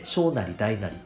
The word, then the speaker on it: 小なり大なり: 不統合の読み方って、小なり大なりって読むんですよね。